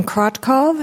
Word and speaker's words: Krotkov: My name is Jasmine Krotkov, and that's spelled K-R-O-T-K-O-V, and I come to you this morning with the Montana Farmers Union.